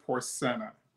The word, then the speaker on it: Porcena: So a lot of people say Porcena, but it's actually Porcena. – Okay. – We like to keep the Haitian or the French accent on the CE part of the name.